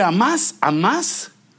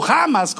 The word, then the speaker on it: Hamás: Por ejemplo, el nombre Hamás, o Hamas, como pronunciamos la H, es un ejército, digamos, del terror.